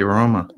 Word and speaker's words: Roma: Is it Roma or Rama? – I would say Roma.